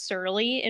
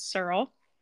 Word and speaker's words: Serle: And her last name is pronounced Serle. I think I said it's Surly in one of the other episodes, but it's Serle.